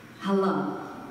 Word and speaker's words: halal: It's spelled H-A-L-A-L, halal. You would see it like H-U-H-L-A-A-L with the emphasis on the last part, so halal.